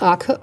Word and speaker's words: Arke: Aus Pia Gant wird Pia Arke, abgeleitet von dem Mädchennamen ihrer Mutter, der nur ein bisschen anders geschrieben wird, aber auch Arke ausgesprochen.